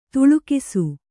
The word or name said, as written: ದಾಲಕ್ಷ